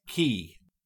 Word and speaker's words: cay: At least pronounce it correctly, Scotty. C A Y is pronounced key.